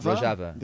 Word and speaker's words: Rojava: Am I pronouncing that word right? Is it Rojava? – Rojava. – The Kurdish homeland in northern Syria.